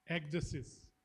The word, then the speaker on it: Ecdysis: Pronounced Ecdysis, which is not a Greek word, it's not found in the Bible, but I thought why not act a little educated.